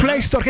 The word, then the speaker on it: Play Store: Play Store.